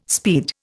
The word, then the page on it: speed: Indian